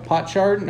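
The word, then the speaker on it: potsherd: How do you say that? Pot shard? What is it? – Shard. – There you go. Pot shard.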